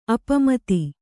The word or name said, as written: ಅಪಮಿತ